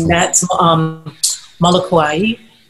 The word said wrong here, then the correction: Molokai: Molokuai. I think that's how you say it. That's Maui. With the Molokai. Molokai. – Moon over Molokai.